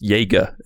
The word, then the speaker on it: Jaeger: Hey, just to let you know, like for the future, you know, it's actually pronounced Jaeger instead of Jag. I think it's a German word.